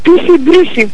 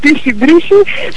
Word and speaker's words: пиши-бриши: Пиши-бриши. Всъщност така е ударението. Пиши-бриши.